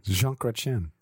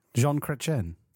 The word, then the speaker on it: Jean Chrétien: Say his name again. – Jean Chrétien. – Jean Chrétien. – There you go. – Jean Chrétien. – Not Sean.